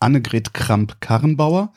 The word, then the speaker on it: Annegret Kramp-Karrenbauer: 46 Prozent der CDU-Anhänger hätten gerne Annegret Kramp-Karrenbauer. Ich richtig ausgesprochen. Applaus.